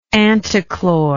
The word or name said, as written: antichloristic